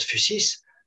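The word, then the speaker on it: fusis (φύσις): Je prononce fusis euh, vous pouvez l'écrire soit PHU, SIS, soit PHY, SIS. De toute façon, euh, c'est euh, la même prononciation. On prononce en général donc FUSIS.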